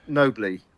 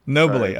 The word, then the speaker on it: Nobile: Is that how you pronounce his last name, the Italian? Oh, so, well, I I pronounce. I've been told it's Nobly. – Nobly.